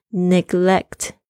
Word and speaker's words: neglect: neglect, n e g l e c t，啊，那个 c t 那个 c 很轻的 neglect，忽视。